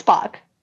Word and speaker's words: Spock: Okay, well, obviously now I know his name's not Spork. Sportikin. – It's Spock. – Spock. – It's Spock.